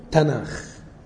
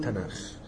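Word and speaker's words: تناخ (תנ״ך): يبقى الاسم هم بينطقوه تناخ، النطق العبري بينطقوه تناخ من التاه والنون والكاف، والكاف ده ساعات بيتقلب نطقه على خاء.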